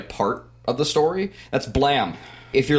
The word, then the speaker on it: Blame!: If you're looking it up, it's spelled Blame! Exclamation point, because Japanese translation. But it's pronounced Blam. It's supposed to be like a gun going off, like boom, blam, blam.